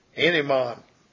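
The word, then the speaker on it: enimon (אמונה): And faith in the Hebrew is 529, and it's pronounced enimon, enimon.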